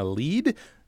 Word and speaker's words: Lede: It's called South Carolina Lede. Am I pronouncing that right? It's L E D. – You are. You are.